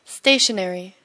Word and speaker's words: stationary: Stationary. – 자, 앞에 있는 stationary는 움직이지 않는 이란 뜻이죠. 움직이지 않는. – Stationary. – 뒤에 있는 것은 발음 같지만 이것은 문방구란 뜻입니다.